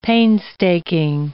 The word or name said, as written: painstaking